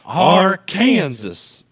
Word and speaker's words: Arkansas: It's pronounced Arkansas.